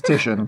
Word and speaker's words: Titian: It's Titian.